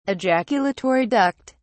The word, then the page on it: ejaculatory duct: British English